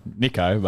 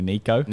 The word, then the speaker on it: Nicho: Hey, do I say it wrong every time? Is it Nicho or Nicho? – Nicho, but some of the poly boys say Nicho.